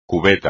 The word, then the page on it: cubeta: espagnol